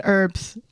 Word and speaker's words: herbs: Why wouldn't you just spell it the right way? H-E-R-B-S. – That's probably automatically generated. – Herbs. – Mm-hmm. Herbs. Because herbs. There's a fucking H in it.